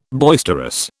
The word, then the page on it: boisterous: British English